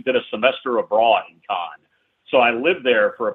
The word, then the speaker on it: Cannes: I know a lot of people pronounce it Cannes. The French pronounce it Cannes.